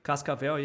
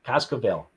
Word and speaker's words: Cascavel: And the team, I'm going to pronounce it wrong. It's a Cascavel, no? – Cascavel, yeah. – Cascavel. That's it.